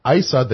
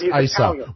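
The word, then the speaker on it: Issa: His mic was cut off because ISA, the chairman of the committee meeting, didn't like what he had to say. – No, it's pronounced ISA.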